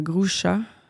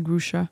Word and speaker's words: Grucza: Le directeur de la prévention des pertes de Toys R Us, Bernard Groucha. Je me rappelle pas comment prononcer son nom. G-R-U-C-Z-A. Il me semble que c'est Groucha.